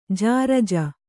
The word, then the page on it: ಜರ್ಜು: jarju